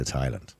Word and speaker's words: Thailand: And every year he went away for 4 or 5 weeks to Thailand. Every year. By the way, can I mention as well, Thailand? There is only two places in the world that have a TH that you don't pronounce the H.